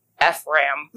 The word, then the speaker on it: Ephraim: P-H-R-A-I-M. Ephraim. – Yeah. Ephraim? I don't know. – People were saying you pronounce it like without the I. Ephraim.